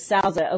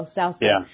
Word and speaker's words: Souza: And then this guy, Felipe de Souza, actually, he's just called Felipe, I think they pronounce it Sousa over there in Mexico, Sousa.